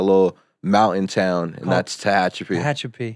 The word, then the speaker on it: Tehachapi: Well, what, you grew up, Nick told me, I can't even pronounce it, I tried 6 times. – You got it. – Tehachapi? – Yes, sir. – The first Tehachapi.